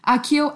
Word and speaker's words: Accio: Is that how you say it? Accio? Accio? – Yeah. – I say Accio. – They say Accio, Accio. I say Accio as well, yeah.